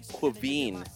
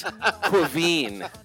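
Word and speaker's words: Caoimhín: C-A-O-I-M-H-I-N is pronounced Quaveen. – That is exactly right. And you put the stress on the end syllable.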